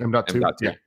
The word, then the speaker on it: M.2: M.2.